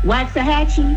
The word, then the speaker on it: Waxahachie: Waxahachie. – Ooh, Waxahachie. Waxahachie.